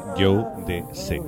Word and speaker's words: Jodeci: Escrito Jo de C, pero según ellos se pronuncia Joe de C, que además lo pone en el disco Forever My Lady.